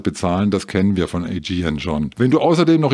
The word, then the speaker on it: Aegean: Also, das heißt nicht Aegean oder Aegean oder Aegean, das heißt Aegean. Tausend Dank, Jörg. Hauptsache, die Community denkt mit und spricht mit. Also, Aegean Star Alliance lockt mal wieder mit 20% Rabatt für Flüge nach Griechenland zwischen 1.5.